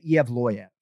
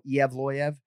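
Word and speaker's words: Evloev: So I sort of say Evloyev, right? Yeah. Evloyev is passable per Michael LaPlante and Zach Candido, our producers, but I actually go Evloyev.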